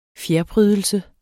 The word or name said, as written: fjerprydelse